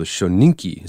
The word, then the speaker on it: Shoninki: It's a translation of a set of Japanese scrolls from the 1600s called the Shoninki.